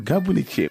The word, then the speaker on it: Gabounitié: Gabounitié, c'est comme ça que vous le prononcez, Vieux Farka. – Gabounitié.